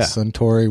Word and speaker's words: Suntory: Am I saying that right? Yeah. Suntory whiskey. Did I get that right? – Yes, sir. You nailed it.